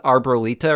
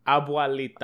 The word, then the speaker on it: abuelita: But, like, you know, his grandma, or Mrs. Arbolita or something. – Grandma. – Abuelita is Spanish for grandmother. Grandma. – How do you say it? – Abuelita.